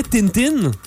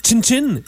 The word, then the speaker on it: Tintin: Parce qu'en japonais, Tintin, ça se prononce Chin-Chin, et ça veut dire petit pénis.